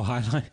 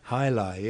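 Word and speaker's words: jai alai: But it's the first time I ever saw, I don't know how you pronounce it, Jai-Alai? – Oh, Hi-Li? – Hi-Li, yes.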